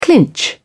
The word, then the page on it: clinch: British English